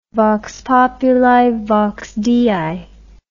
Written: vox populi vox dei

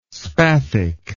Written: spathic

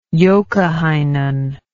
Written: joukahainen

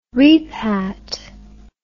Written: repat

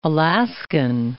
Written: Alaskan

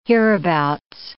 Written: hereabouts

